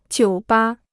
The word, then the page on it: 酒吧: jiǔ bā